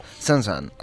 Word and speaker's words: Saint-Saëns: Molt bé, comencem amb, crec que és, Saint-Saëns? – Saint-Saëns,